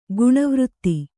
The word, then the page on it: ಗಣವ್ರಿತ್ತಿ: gaṇa vritti